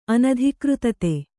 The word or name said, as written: ಅನಧಿಕೃತತೆ